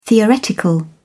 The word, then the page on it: theoretical: British English